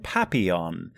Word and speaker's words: Papillon: All right, you played Papillon. – Close. – Is that correct? – Close. That's what, Papillon? You know what, I had to look it up. Papillon. It is, it's French, I understand. Papillon. P-A-P-I-L-L-O-N.